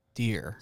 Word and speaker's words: Duerr: Duerr, not Duerr? – As in the animal Duerr.